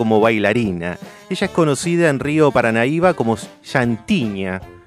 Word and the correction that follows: Santiña: ¿Lo dije bien? No, es muy bueno mi, portugués. Santiña, una.